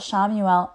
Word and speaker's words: Chamuel: All right, and so the last Archangel we're going to talk about today is Archangel Shamuel. It's Chamuel. I say that because it doesn't, you wouldn't think that's how you would pronounce it, but it is.